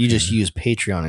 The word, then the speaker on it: Patreon: Well, the site is Patreon, but you're a patron. – So you're a patron of Patreon.